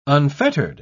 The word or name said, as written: unfettered